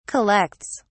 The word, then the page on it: collects: British English